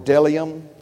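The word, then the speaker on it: bdellium: I believe that you pronounce that word Delium, and the onyx stone are there.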